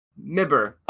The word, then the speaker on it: MIBR: So now can you stop talking about Mibber and start talking about a team that's actually going to play at the event like MIBR? – No. It's pronounced Mibber.